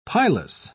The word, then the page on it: pilous: British English